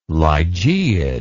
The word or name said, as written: lygaeid